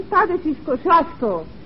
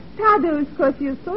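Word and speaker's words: Tadeusz Kościuszko: In Poland, we say his name Tadashis Kosciuszko. But in America, Tadeusz Kosciuszko is fine.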